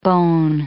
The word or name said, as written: bone